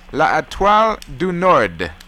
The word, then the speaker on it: L'Etoile du Nord: The state motto is L'Etoile du Nord.